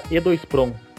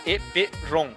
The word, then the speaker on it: EEPROM: O pessoal do eletrônico fala E2PROM. – É, eu vou falar EEPROM.